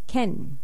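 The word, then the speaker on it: ken (けん): And the pronunciation of dog is either inu, and also sometimes ken.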